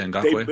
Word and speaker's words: Ngakoue: I, I can't even pronounce his name, Nakakwe, whatever his name is. – Yeah, Ngakoue.